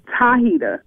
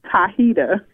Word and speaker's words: Tahita: Tahita with a T. – Oh, all right, Hahita. All right, baby. – Tahita.